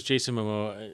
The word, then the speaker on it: Jason Momoa: D- is it me or does Jason Momoa? Uh, did I say the name right? – Yeah, yeah.